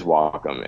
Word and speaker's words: Wacom: But yeah, it is Wacom. And I mispronounced it for years.